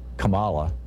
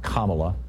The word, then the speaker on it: Kamala: I realize there may be some challenges to Kamala, and interestingly, the song pronounced it Kamala, but I think it's actually pronounced Kamala, like calm, Allah.